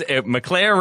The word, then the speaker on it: McClair: Because McClair rhymes with Eclair.